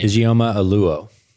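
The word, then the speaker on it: Ijeoma Oluo: Ijeoma Oluo.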